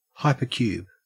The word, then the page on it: hypercube: Australian English